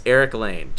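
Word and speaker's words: Erich Lane: It's Eric Lane.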